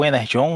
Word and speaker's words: Energon: Bom, o, o Energon, né, apesar de escrever com G, é pronunciado como se fosse um J, essa é uma, uma maluquice franquícia aí desde os princípios da, da franquia.